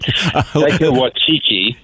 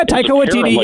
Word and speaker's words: Taika Waititi: Taika Waititi. – Yeah, Taika Waititi.